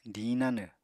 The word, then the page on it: diñ nanw: apatani